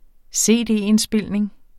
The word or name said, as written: cd-indspilning